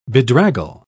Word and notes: bedraggle: British English

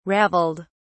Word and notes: raveled: British English

